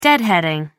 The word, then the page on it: deadheading: American English